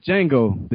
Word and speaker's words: Django: My man Django, the D is silent.